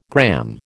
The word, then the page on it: gramme: American English